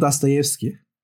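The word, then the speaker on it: Dostoyevski (Достоевский): Ee, yanlış öğretmedilerse, yanlış uyarmadılarsa beni esas olarak Rusça okunuşu Dostoyevski.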